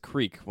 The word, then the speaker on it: creek: You don't pronounce it as creek when you're in Delaware. What? – You just pronounce it crick.